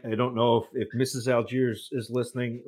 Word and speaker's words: Allgeier: But then I also want to get your thoughts on Atlanta drafting Tyler Allgeier, if I pronounce that correctly. – Sure did. – So first, let me tackle Allgeier.